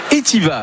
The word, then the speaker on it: Etivaz: Il s'agit de l'AOP Etiva. Alors, ça s'écrit Etivaz, mais en Suisse, visiblement, on prononce pas... le Z.